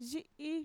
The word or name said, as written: yi'i